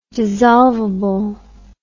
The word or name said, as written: dissolvability